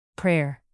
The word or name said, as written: prayer